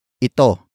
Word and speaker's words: ito: The next word is ito. Ito. Ito. Ito is spelled as I-T-O.